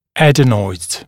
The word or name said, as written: adenoids